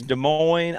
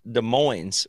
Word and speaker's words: Des Moines: It's not a silent S. – There's Des Moines, Iowa. It's definitely Des Moines, Iowa. But in Washington, here in Seattle, there's Des Moines, which is pronounced the S, and it's spelled exactly the same, so that can be a little confusing.